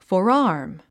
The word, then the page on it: forearm: fɔːrάːrm; フォラァーム